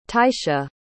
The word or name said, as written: teisha